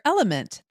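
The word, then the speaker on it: LMNT: Sarah, we're welcoming our sponsor, Element. That's spelled L-M-N-T, a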